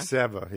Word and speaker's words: Seva: Seva, yes.